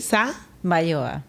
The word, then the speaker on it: Samayoa: pero su nombre es María Isabel Sa. Mayoa. – Samayoa. – Yo, siempre lo digo mal.